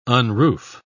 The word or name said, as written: unroof